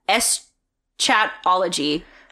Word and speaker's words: eschatology: It was about the apocalypse, which is called eschatology. – Yes, totally pronounced that 100%. – Yep. That is exactly what it's called.